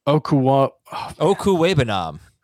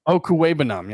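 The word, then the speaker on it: Okwuegbunam: But I think the bigger thing with his return is, and the return of, hold on, I've got the pronunciation, Albert Okuwebanam. – Okuwebanam, yes.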